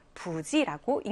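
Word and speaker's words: 부지 (不知): 그래서 여기서도 생년불지가 아니라 부지라고 읽는 거예요.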